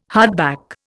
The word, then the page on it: hardback: Indian